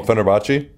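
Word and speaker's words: Fenerbahçe: Oh, Fenerbahce? – Yeah, I'm just bad at it. – Yeah. – So he's going back to Fenerbahce.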